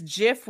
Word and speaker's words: GIF: It is GIF with a J. The person who invented it said that that is how it's pronounced.